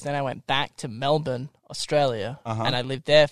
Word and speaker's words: Melbourne: Can you say it right? – Melbourne. – Don't say it like that. – How do you say it? – Melbourne. – Melbourne. – Thank you. – Burn. – Burn. – Burn. – Burn. – But it's spelled Melbourne.